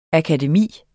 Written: akademi

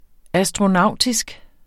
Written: astronautisk

